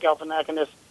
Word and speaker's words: Galifianakis: I mean, you know, obviously Zach Galifianakis, and I think I said his name right. – You did.